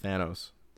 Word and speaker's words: Thanos: Thanos.